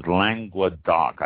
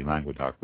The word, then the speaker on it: Languedoc: And this is Languedoc, I think it's Languedoc, L-A-N-G-U-E-D-O-C, Languedoc. I believe that's how it's pronounced.